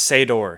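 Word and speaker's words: Sator: Elizabeth Debicki plays Kat, who's Sado's wife. – It's Sador.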